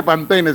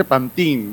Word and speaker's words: Pantene: Tú me dijiste que no se dice Pantene, se dice, te salió el Google Traductor, se dice Pantín.